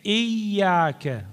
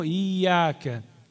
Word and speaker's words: إياك: الصحيح إياك مو إياك. الحمد لله رب العالمين الرحمن الرحيم مالك يوم الدين إياك نعبد، هذا غلط. هذا الهمزة مال إياك همزة قطع، يعني تلفظ وتكتب، بينما همزة الوصل تكتب ولا تلفظ، هذا الفرق بينات.